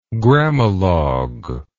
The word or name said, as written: grammalogue